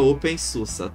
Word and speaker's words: OpenSUSE: E só uma curiosidade aqui, que tem um sistema, uma distro Linux, que o pessoal chama pelo nome errado, que é o OpenSUSE, que na verdade se pronuncia OpenSUSE, tá bom? Então, quando vocês irem escrito SUSE, SUSE, pronunciem SUSE.